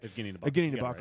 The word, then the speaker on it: Evgeni Nabokov: Same thing with Evgeni Nabokov. Like, I hope I pronounced that right. – Evgeni Nabokov. – Right. – Yeah, you got it right.